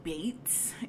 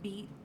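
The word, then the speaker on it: Beetz: And there's a bit of controversy surrounding the casting of Zazie Bates, is how you pronounce her name. It's not Beats.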